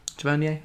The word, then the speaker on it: Tavernier: Tavernier, right? – Tavernier, okay.